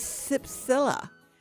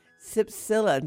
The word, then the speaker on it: cypsela: And the dirty word of the day is Cypsilla. Cypsilla, that's spelled C-Y-P-S-E-L-A. Unlike the international recognized terms of taxonomy,